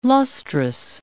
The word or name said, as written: lustrous